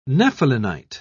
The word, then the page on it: nephelinite: British English